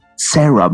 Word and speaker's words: Cera: Is it Cera? Is that how you pronounce it? C-E-R-A? Sarah, Michael Cera, sorry.